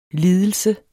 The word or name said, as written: lidelse